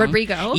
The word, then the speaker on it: Rodrigo: And even for one ticket that Olivia, I always say her last name wrong, Rodrigo,